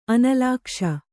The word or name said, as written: ಅನಲಾಕ್ಷ